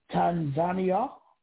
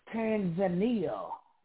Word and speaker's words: Tanzania: Tanzania. – Americans say Tanzania. But they say Tanzania.